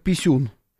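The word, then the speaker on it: P1-SUN: Встречался он там с Зеленским, фотографировался с дроном украинским, дроном-перехватчиком, который называется латинскими буквами P1 дефис Sun, что читается по-русски как писюн, никак иначе.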